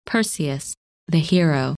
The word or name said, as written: Perseus